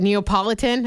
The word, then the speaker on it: Neapolitan: 4210 said, I hope I say this right, Neapolitan. I always feel like I say it wrong. – No, that was right.